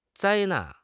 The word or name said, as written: 灾难